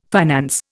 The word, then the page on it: finance: Indian